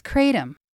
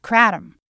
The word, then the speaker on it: kratom: But what is Kratom? Or is it called Kratom? Well, pronunciation does vary, and both are widely used. The Oxford Dictionary says that both pronunciations are actually correct, and you'll find experienced, knowledgeable users who insist on one pronunciation or the other. But both are objectively correct.